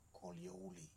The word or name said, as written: Karawari